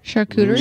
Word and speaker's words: charcuterie: Charcuterie.